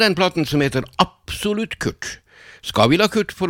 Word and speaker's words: QRT: Og det kan jo veldig lett uttales Kurt, og det meningen også.